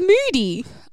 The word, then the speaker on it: Mudi: Like double O? Like when I'm feeling sad and angry, I get moody? – No. – Oh. – Okay. But that's how it's pronounced, yeah. M-U-D-I.